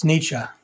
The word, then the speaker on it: Nietzsche: You said it correctly. It's Nietzsche.